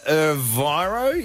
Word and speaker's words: Erivo: And Cynthia Erivo, is that how you say her name? – Yeah, which is Elphaba.